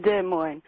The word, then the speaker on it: Des Moines: It's Des Moines. – Des Moines. – Des Moines, it's French. Nobody says it right.